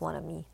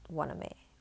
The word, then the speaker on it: Wanami: Yeah, it's pronounced Wanami, not Waname or Waname. It's Wanami.